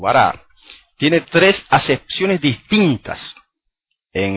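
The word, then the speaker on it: bará (ברא): Fíjense, la palabra creó, dice en hebreo, dice bará, aunque algunos lo pronuncian baró. El autor de que les mencioné al principio, Mordejai Ederi, la pronuncia baró.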